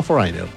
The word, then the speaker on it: Foreigner: Yo puse cómo se pronuncia Foreigner. Y yo escuché y era Foreigner, no Foreigner. Foreigner.